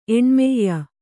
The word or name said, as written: ಎಣ್ಮಯ್ಯ